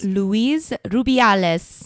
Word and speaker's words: Luis Rubiales: Luis Rubiales.